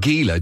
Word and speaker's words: Gila: It's spelt Gila, G-I-L-A. – Cool. – But apparently it's pronounced Gila. So today's today's monster is the Gila monster.